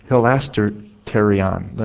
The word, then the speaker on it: hilasterion: Now, as you go to the Greek word for propitiation, it is the Greek word hilasterion. Let me spell it for you. H-I-L-A-S-T-E-R-I-O-N.